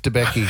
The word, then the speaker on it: Debicki: Elizabeth Debicki.